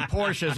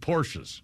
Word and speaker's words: Porsche: I grew up as a kid, I went to prep school, I had lots of friends with Porsches. And not one of them said Porsche. – I know. – It's all Porsche. – I know. But that's the way it's supposed to be pronounced.